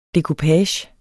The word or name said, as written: dekupage